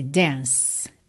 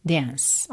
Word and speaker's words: dance: Então, aqui você já reparou que na palavra dance, nós temos uma letra E no fim dessa palavra, mas ela não é pronunciada, está pintadinha de cinza aqui para você lembrar de deixá-la completamente muda. Então, let's dance. Você termina no som do C, que acaba soando como um S, dance, dance, ok?